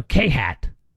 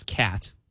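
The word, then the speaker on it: khat: So, K-Hat. Is that how you say it? – I think it's cat.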